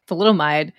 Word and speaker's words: thalidomide: Oh, you should definitely tell us about thalidomide.